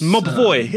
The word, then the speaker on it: Mobvoi: The Tick Watch Pro is the new smartwatch by Mobvoi. Is that how you pronounce the name? – Ooh, that actually looks... – Mobvoi. Yeah, that is their name.